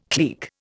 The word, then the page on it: clique: Indian